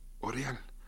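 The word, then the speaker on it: L'Oréal: así se pronuncia, se escribe, aureal. En francés la AU se pronuncia O, aureal.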